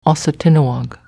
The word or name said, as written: Aussatinoag